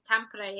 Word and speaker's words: Tampere: So you're there, you're in Tampere, Finland? Did I pronounce that right or have I butchered that? – Yeah, it's Tampere, yeah. – Tampere, right.